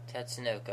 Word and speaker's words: Tatsunoko: Tatsunoko? – Tatsunoko, okay, there we go.